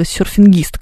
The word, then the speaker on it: серфингист: как в слове «серфингист», к примеру. Здесь такое полуударение, такое, знаете, неочевидное, потому что «серфинг» понятно, да, а «серфингист» все-таки ударение на последнюю «и»,